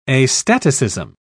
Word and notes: astaticism: British English

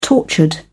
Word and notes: tortured: British English

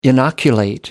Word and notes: inoculate: American English

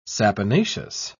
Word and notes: saponaceous: British English

